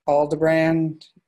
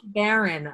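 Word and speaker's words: Aldebaran: Did I get that Aldebrand correct? – Aldebarand, I believe it's pronounced. It's actually the name of a star, and it's the star that's associated with the Taurus constellation in the sky, and it's the sort of the bull's eye.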